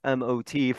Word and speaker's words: MOT: You'll correct me, coach, if it's Mott or if you actually pronounce M-O-T for the charter for the high school. Coach is joining us. Coach, how are you doing? And how is the actual pronunciation of the high school you currently coach at? – Thanks, Coach Hernandez, for having me on. But it is M-O-T charter. Okay. M-O-T charter is how you pronounce it. M-O-T. – And what does the M-O-T stand for? – Middletown, Odessa, Townsend.